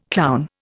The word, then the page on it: clown: Indian